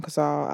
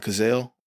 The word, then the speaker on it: Cazale: You just brought up a name that I want to speak about, and I never know how to pronounce his last name. Um, uh, John Cazale. Cazal, Cazal. Okay, John Cazal, Cazal.